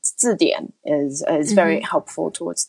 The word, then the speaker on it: 字典: In Chinese, we say 字典.